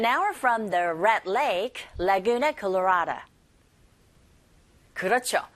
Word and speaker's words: Laguna Colorada: 발음하시면 되고 la, la, Laguna Colorada 라고 발음하시면 됩니다.